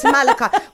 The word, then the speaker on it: malachite: How do you say that? Because I want to say malachite. – No, it's malachite.